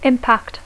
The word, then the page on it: impact: English